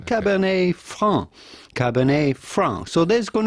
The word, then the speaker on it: Cabernet Franc: Cabernet Franc, Cabernet Franc, but the T at the end of Cabernet is still silent, Cabernet Franc. But for the typical French pronunciation, you do not want to pronounce the C at the end of Franc because it is silent in French, as many letters, last letters of words are just like the T of Cabernet.